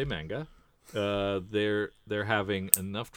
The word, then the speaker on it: manga: During episode 502, you ask about how manga is produced. – Here oh, we go again. – The ma sound, sound is pronounced like the ma in mama, not the ma in man.